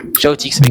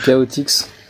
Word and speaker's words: Chaotix: Shoutix. Ouais. – Oui, oui, ça se prononce Chaotix encore, ou un truc comme ça, ou je me suis encore fait baiser.